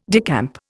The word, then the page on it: decamp: Indian